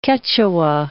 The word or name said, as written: Quechua